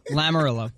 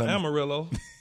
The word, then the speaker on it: Lamoriello: Lamoriello. Lamoriello, not Amarillo.